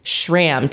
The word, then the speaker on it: Schramm: And with me today, I have our expert, Dr. Dave Schramm.